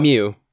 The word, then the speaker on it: Miu: Chapter 38, Nana is helped through her attack by Yasu, Nobu, doormate Miu, and I'm going to make sure to really say Miu, not Miu. Miu. – Yeah. – Yeah. Two syllables, Miu,